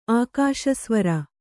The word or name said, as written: ಆಕಾಶಸ್ವರ